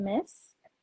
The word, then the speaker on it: Myss: So essentially, the woman who wrote this book, Carolyn Miss. Yeah, it's a really, really good book. It's called Anatomy of the Spirit, and the author is Carolyn Miss, but it's spelled M-Y-S-S instead of the typical M-I-S-S.